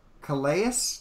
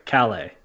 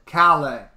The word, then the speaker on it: Calais: Raymond Calais? Damn it, I said it wrong. – It's Calais. – Calais.